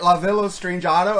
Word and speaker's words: Strangiato: For years I used to call it Strangiato until I heard Getty say it, La Villa Strangiato. I go, oh, that's how you say it.